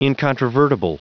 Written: incontrovertible

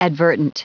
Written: advertent

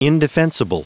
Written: indefensible

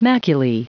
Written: maculae

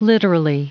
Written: literally

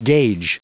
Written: gauge

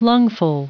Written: lungful

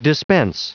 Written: dispense

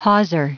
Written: hawser

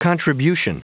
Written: contribution

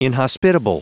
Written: inhospitable

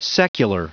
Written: secular

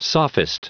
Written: sophist

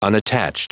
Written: unattached

